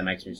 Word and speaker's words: Meixner: Meixner's test? Is that how you say it? – Yeah, the Meixner's test.